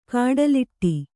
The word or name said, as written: ಕಡಲಾಡಿ